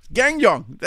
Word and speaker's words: Guangdong: Guangdong.